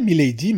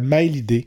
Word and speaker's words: Mailydee: Je l'appelais Milady, mais c'est pas comme ça qu'il faut prononcer son nom d'artiste. C'est Mailidé. Donc Mailydee, accent aigu E.